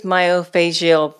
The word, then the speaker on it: myofascial: And what is myofascial pain, and how does it pertain to pelvic pain? And you can correct me if I pronounce that wrong. – No, perfect.